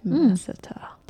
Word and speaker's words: masseter: Masseter. Is that how you say it? Masseter. – It is. – Masseter. No, just masseter. Masseter's fine. All right.